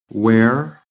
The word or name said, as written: WEAR